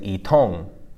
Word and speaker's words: yitong: And that's spelled Y-I-T-O-N-G, right? So second tone, fourth tone, itong. And I don't ever recall pronouncing the Y. I'm pretty sure it's just if Y is before I, then you don't pronounce it.